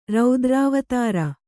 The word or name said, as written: ರುದ್ರಾವತಾರ